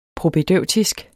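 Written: PROPÆDEUTISK

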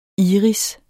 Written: iris

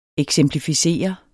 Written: eksemplificere

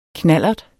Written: knallerts

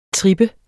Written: trip

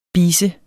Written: bise

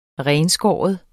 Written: renskære

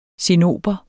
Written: cinnober

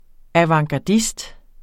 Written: avantgardist

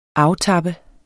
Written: aftappe

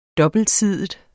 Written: dobbeltsidig

